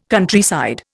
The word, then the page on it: Countryside: Indian